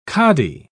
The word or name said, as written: cadi